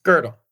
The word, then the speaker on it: Gödel: And where he argues that you pronounce it Gödel? – Gödel. Yeah.